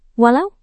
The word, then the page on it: walau: Indonesian